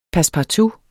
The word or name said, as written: passepartout